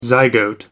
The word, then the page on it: zygote: American English